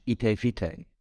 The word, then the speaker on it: Ite Vitae: And it rhymes in Dutch, Ite Vitae.